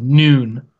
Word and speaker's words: Nwnn: We have a friend of ours that goes by the moniker of Noon, and that's spelled in the traditional Welsh spelling of NWNN.